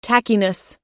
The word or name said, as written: tackiness